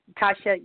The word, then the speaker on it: Tasha: My name is Tasha. – Tasha? – Tasha, yes. – How do you spell that? – T-A-S-H-A.